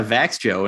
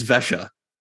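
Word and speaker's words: Växjö: I was like, yeah, Vax Joe. And he's like, excuse me. – Nakwa. Like, come on. – No, wrong. It's Vesha.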